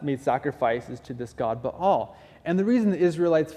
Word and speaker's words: Baal: Your dad has this altar to this false god named Baal, or Baal is how it's properly pronounced, but I've heard so many people say Baal that I'm going to use the two interchangeably, so sorry.